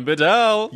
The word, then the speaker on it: Bedell: Colin Bedell. – You got it. See, you didn't need me to say it. – I put the emphasis on the right syllable.